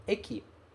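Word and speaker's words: Eki (駅): Como é que ele se pronuncia? Eki. Eki, estação, ok?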